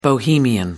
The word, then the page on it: bohemian: American English